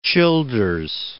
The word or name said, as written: Childers